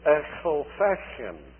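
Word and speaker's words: Ecclefechan: I passed through that village, which they would ordinarily use in order to teach children and others, foreigners, how to pronounce ch properly. And it was the village of Echelfechin.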